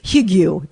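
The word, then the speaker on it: hygge: Hugu, that's how you say it.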